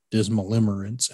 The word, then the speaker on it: Dismalimerence: Actually, I was trying to find the definition for Dismalimerence, and I don't know if I'm pronouncing that correctly or not.